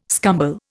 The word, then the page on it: scumble: Indian